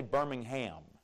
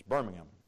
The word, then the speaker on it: Birmingham: You don't say Birmingham. They'll correct you, you know. It's Birmingham, Birmingham. And you kind of say it all together, Birmingham, making it about one syllable, Birmingham.